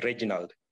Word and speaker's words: Reginald: I would like you to pronounce my name as Reginald. You know, Regina is a female, but the male version is Reginald.